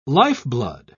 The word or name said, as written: lifeblood